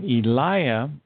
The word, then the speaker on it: Elijah: And Eliah is the correct way to pronounce Elijah.